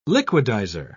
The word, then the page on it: liquidiser: British English